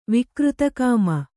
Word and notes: ವಿಕೃತಕಾಮಿ: vikřta kāmi

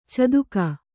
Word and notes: ಚದುಕ: Kannada; caduka

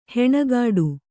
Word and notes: ಹಣೆಕಟ್ಟು: Kannada

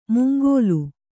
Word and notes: ಮುಂಗೋಲು: mungōlu

